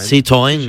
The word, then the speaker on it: Citroën: ¿Qué tal el Citroën de Alex de Llano? ¿Lo Citroën. – Dice bien? – ¿El Citroën? – No, la pronunciación. – Citroën. – Citroën. – Sí, muy bien.